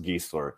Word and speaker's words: Giesler: And the first sentence is, to get it out of the way, it's pronounced Giesler, to rhyme with geese.